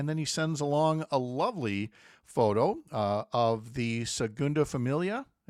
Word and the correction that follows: Sagrada Familia: Did I say that right? I believe so. – Sure. – The Sagrada Familia.